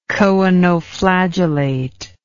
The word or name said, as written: choanoflagellate